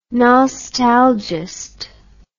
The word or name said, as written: nostalgiast